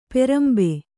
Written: ಪಾರಂಬೆ

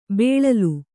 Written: ಬೆಳಲು

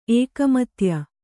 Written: ಏಕಮತ್ಯ

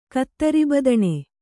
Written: ಕತ್ತರಿಬದಣೆ

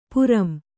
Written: ಪಾರಂ

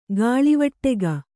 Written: ಗಾಳಿವಟ್ಟೆಗ

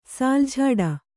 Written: ಸಾಲ್‍ಝಾಡ

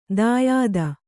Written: ದಾಯಾದ

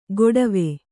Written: ಗೊಡವೆ

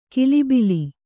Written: ಕಿಲಿಬಿಲಿ

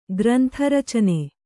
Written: ಗ್ರಂಥರಚನೆ

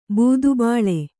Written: ಬೂದುಬಾಳೆ

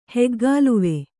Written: ಹೆಗ್ಗಾಲುವೆ

